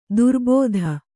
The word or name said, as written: ದುರ್ಬುಧ